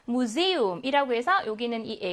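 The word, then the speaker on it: Museum: 복모음이 활용이 된게 아니라 각각의 모음으로 본다. 그래서 에 하고 우로 발음합니다. 무세움이라고 발음하거든요.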